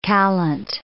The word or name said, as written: callant